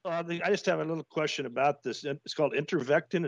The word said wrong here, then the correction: Ivermectin: Is that how you pronounce it? – Ivermectin.